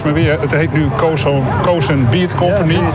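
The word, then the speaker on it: Cosun Beet Company: Hoe moet je het uitspreken? Suikerunie, dat is hoppa en ja, en dit is een ja, mondvol ben ik. – Cozen Beat Company.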